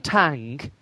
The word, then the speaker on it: Tang: And I'll also share out Alan Tang. And the reason for this is because his last name rhymes with Wang.